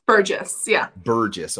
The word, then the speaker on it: Burgess: Burgess. – Okay, that's how you say it. – I answer to Burgess.